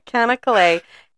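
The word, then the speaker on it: Çanakkale: I looked up how you say it. Kanakale.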